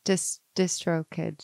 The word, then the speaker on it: DistroKid: DistroKid? Is that how you say it? I've never said it out loud before. – I think so, yeah.